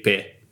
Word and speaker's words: paix: Is it like pays? – No, you don't pronounce it. – Pay. – Exactly. – That is? – So it's war and peace. – Word. – So it's guerre et paix. – Oh, paix. – Paix. Yeah, exactly.